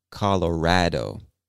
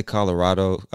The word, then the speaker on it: Colorado: Now, some people, especially people from the state, I hear them pronounce this as Colorado. I say Colorado. Other people say Colorado. There's no incorrect or correct way. You can pronounce it either way, and it's totally fine.